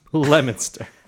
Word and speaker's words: Leominster: In Massachusetts, L-E-O is pronounced le. – I guess... – Leominster.